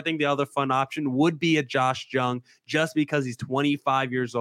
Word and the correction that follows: Jung: It's a soft J, by the way. – Josh Young.